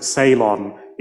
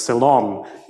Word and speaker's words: Ceylon: So to pronounce it Ceylon is a troche because the stress comes on the first syllable, whereas Ceylon is an iam because, you know, stress comes on the second syllable.